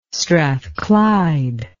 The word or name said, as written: strathclyde